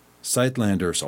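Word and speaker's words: Suidlanders: And then go and check out their website, Sightlanders. I'll spell it for you. S-U-I-D. It looks like suid. S-U-I-D. Landers. L-A-N-D-E-R-S.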